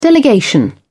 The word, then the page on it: delegation: British English